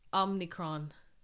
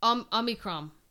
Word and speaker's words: Omicron: It's Omicron. No, it's not. – Yeah, but that's exactly what you think it'd be. – No, it's not. Omicron. It's no N. It's not Omni.